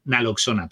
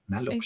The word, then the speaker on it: naloxona: El Narcan es el nombre comercial y la molécula es la naxolona y naloxona, perdón. Naloxona.